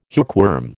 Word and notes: hookworm: American English

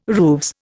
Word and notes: rooves: Indian

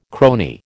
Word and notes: crony: American English

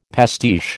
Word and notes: pastiche: American English

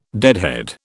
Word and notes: deadhead: British English

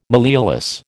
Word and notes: malleolus: American English

